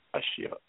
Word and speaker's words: Ashil: Ashiel, A-S-H-I-L.